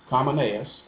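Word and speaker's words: Kamoneyas: I never would have thought about that, but she has a Facebook page, and it's called Kamaneas.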